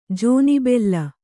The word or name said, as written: ಜೋನೆಬೆಲ್ಲ